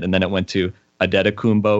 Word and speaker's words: Antetokounmpo: I go with Adetokounmpo. That's what it sounds like how he pronounces it.